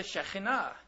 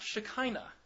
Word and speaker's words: Shekhinah: The major concept that I'm going to start with is that of the Shekhinah. You're probably used to hearing that pronounced Shekinah.